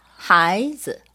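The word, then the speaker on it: 하이즈 (孩子): 자 발음은 다시 해야겠어요. 네. 여기 네. 보세요. 자, 네. 딴 딴. – 하이즈. – 여러분 이때도 턱을 올렸다가 제자리로 오면 됩니다. 하이즈.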